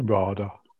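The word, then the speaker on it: RADA: She's, I'm not quite sure how you pronounce the word, is it... Radar trained. – Radar. – It's a radar. – It's radar, yes.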